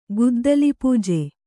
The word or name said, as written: ಗುದ್ದಲಿಪೂಜೆ